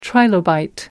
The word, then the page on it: trilobite: American English